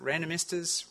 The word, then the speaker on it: randomistas: It's no coincidence that randomistas rhymes with sandinistas.